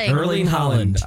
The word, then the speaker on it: Erling Haaland: Tom Holland, aka Spider-Man, apparently DM'd Erling Haaland. – Almost. – Soccer player? – So close. – I don't know how to say it. – Erling Haaland.